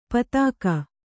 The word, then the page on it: ಪೈತ್ತಿಕ: kannada